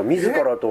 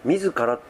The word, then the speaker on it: 自ら: いや、そうじゃないですか、自らとおのずからって知ってる。 – 自らとおのずから、自分の字って書いてさ、自らって読むのとおのずからって読む時あるじゃん。